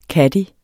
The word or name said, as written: caddie